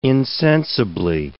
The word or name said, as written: insensibly